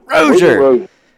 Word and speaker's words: Rozier: Rozier.